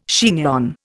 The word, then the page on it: chignon: Indian